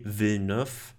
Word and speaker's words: Villeneuve: Und zwar ist das Dune von Denis Villeneuve oder Denis Villeneuve, je nachdem, wie französisch man ihn ausspricht. – Genau, ich glaube, er ist sogar Franzose, also mhm. – Denis Villeneuve würde er ausgesprochen werden. Ähm, ja, Dune oder Dune, da gibt es interessanterweise diese zwei unterschiedlichen Aussprachen, äh, also Aussprachen des Filmtitels.